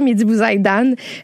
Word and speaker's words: Mehdi Bouzaïdan: Mais parle-nous de Mehdi Bouzaïdan, j'espère que je le prononce comme il faut. – C'est parfait, Mehdi Bouzaïdan, qui a présenté cette semaine son spectacle Demain, qui était présenté à la 5e salle de la Place des Arts.